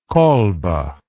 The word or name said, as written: kolbe